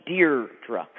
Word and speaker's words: Deirdra: And I pronounce my name Deirdre.